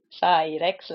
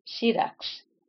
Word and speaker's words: Syrax: They say a lot of people, they say, call her Cyrex, like T-Rex, and it's actually Syrax.